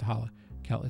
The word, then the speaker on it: Hallikel: One of them, though, is a kind of merged version of Hollowell and Kell, who I am referring to as Hollikel, and I want to point out that's spelled H-A-L-L-I-K-E-L, not Hollow. It's Holle. It's Hollikel.